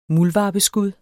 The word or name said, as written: MULDVARPESKUD